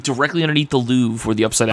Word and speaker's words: Louvre: Right, it's pronounced Louvre.